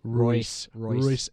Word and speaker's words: Reus: Not that Toni Kroos or Marco, I always say it wrong, Marco Reus. – Royce. – It's hard because the E and the U, I always say that wrong. And that's not like me being goofy trying to mispronounce it. I always struggle with Reus.